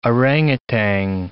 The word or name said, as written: orangutan